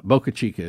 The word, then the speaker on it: Boca Chica: Boca Chica is right.